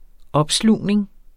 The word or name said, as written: opslugning